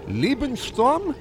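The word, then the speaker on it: Liebestraum: We got Mike DiNapoli coming up with Liest Liebensturm. Is that how you pronounce that? – That's pretty close, yes.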